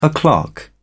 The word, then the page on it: o clock: British English